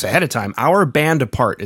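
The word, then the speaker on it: Bande à Part: So Our Band Apart is correct pronunciation, but we don't expect anybody to... Well, I don't expect anybody in America to get that right.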